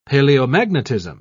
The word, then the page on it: paleomagnetism: British English